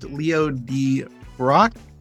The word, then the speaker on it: Leo Debroeck: Today we have a special guest, Leo Debroeck.